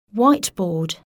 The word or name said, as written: whiteboard